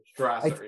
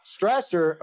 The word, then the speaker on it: Strasser: I don't know if I pronounce his name correctly. – Strasser, I, yeah. – Strasser,